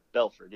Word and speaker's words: Belford: Uh, Belford.